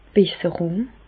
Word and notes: d' Besserùng: Bas-Rhin; Reichshoffen

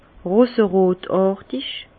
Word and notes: roserotàrtig: Bas Rhin; Herrlisheim

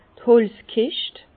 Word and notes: d' Holzkischt: Bas-Rhin; Herrlisheim

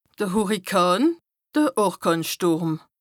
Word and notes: de Hùrrikàn, de Orkànstùrm: Bas-Rhin; Strasbourg

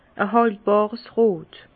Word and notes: e hàltbàrs rot: Bas Rhin; Herrlisheim

